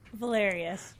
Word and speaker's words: Valerius: Valerius. – Valerius, which rhymes with hilarious.